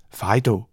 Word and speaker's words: FIDO: Auf Englisch wird es FIDO ausgesprochen.